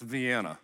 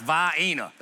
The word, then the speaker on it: Vienna: One of you Raleigh smarties at Thursday night service came up to me and was like, it's pronounced Vienna. Not where I'm from, it's Vienna. That's how you say it.